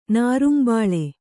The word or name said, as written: ನಾರಿಂಬಳ್ಳಿ